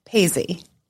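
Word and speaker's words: Paizzi: Paisy.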